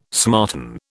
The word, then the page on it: smarten: British English